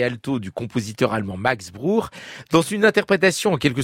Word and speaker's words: Bruch: Bruch, comme on prononce Ernst Bloch et comme on devrait prononcer Jean-Sébastien Bach.